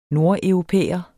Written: nordeuropæer

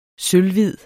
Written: sølvhvid

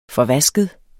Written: forvasket

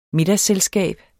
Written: middagsselskab

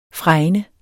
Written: fregne

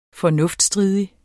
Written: FORNUFTSTRIDIG

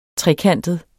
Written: trekantet

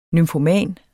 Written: nymfoman

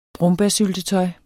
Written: brombærsyltetøj

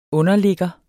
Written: underligger

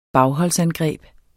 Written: BAGHOLDSANGREB

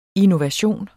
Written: INNOVATIONEN

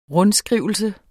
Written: rundskrivelse

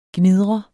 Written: gnidret

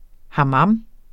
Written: hamam

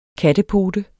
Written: KATTEPOTEN

